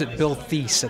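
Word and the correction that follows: Theiss: at the time. – Right. – And they corrected me. They said, no, it's Bill Tice. – I thought it was Thies. – Yeah, it's Tice.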